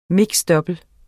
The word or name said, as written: double